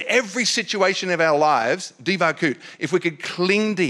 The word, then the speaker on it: devekut: D-E-V-E-K-U-T, but it's pronounced divakut.